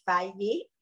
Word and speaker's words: Faye: C'est une adaptation du livre de, de Gaël Fayet. Je, je m'excuse pour la prononciation, c'est F-A-Y-E.